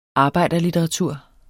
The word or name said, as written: arbejderlitteratur